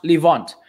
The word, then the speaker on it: Levant: And I think even in English it's pronounced Levant.